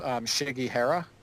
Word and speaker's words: Shigihara: So Laura, I think it's Shigihara. I think that's how you pronounce it.